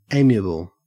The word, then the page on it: amiable: Australian English